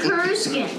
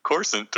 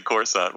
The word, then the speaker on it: Coruscant: It's me always, I'm used to saying Kuruskin. – All right. – Not Kuruskin.